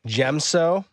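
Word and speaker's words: Djem So: So Ahsoka uses form 5, specifically the Demso variant. Is that how you pronounce it? – I believe you. – Let's try it. – Be Gemso. – Gemso? – Like Jaren, Den Jaren. Gemso.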